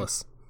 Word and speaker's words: Savalas: Savalas.